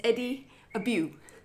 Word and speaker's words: Eddie Abbew: This man is Eddie Abbew.